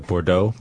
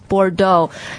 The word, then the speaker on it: Bordeaux: Yeah, Bordeaux. – Bordeaux. 这个呢大家也要注意它的发音啊，因为你看它的拼法的话是 B-O-R-D-E-A-U-X。 – Yeah, E-A-U-X. – 都不发音。 – It's not E-O-X. – Bordeaux. – It's O, Bordeaux. – Bordeaux, 对。所以中文这个翻译过来波尔多其实是蛮像的啊。 Bordeaux.